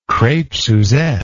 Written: crepe suzette

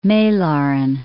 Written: Malaren

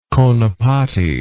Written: conepati

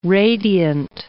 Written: radiant

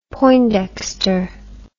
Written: poindexter

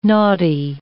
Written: naughty

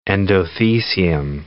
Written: endothecium

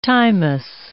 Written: timeous

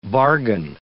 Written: bargain

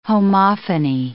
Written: homophony

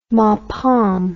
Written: Mapam

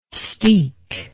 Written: steek

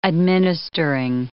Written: administering

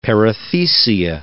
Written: perithecia

